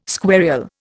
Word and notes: squarial: Indian

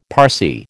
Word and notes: Parsee: American English

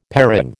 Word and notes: parang: American English

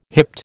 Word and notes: hipped: American English

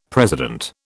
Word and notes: president: British English

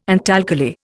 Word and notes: antalkali: Indian

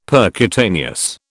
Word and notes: percutaneous: British English

